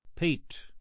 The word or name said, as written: peat